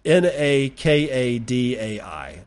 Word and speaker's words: Nakadai: is this note on Nostr from Nakadai. I think that that's how you pronounce it. N A K A D A I. Nakadai. That's how I've always pronounced it.